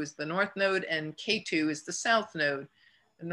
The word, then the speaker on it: Ketu: Rahu is spelled R-A-H-U, and Ketu is not K in the number two, you know, like the mountain. it's a K-E-T-U, Ketu.